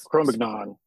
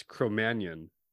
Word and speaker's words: Cro-Magnon: It's Cro-Magnon. – No. Because it's French, the G falls off. It's Cro-Magnon.